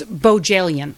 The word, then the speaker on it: Bohjalian: Do you want to know how to pronounce the name? – Yes. – It's Bojalian.